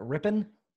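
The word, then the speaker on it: Rypien: Who the hell is Brett Ripien? – Sir, that's Brett Rippin. – Rippin? Is that how you pronounce it? – Rippin. His uncle was Mark Rippin.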